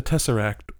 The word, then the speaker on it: Tesseract: And it goes without saying, the Tesseract will not be there.